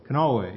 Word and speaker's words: Kanawha: I remember and actually have a copy of the Hollow Rock String Band record on, however it's pronounced, Kanawha? – Kanawha, yeah.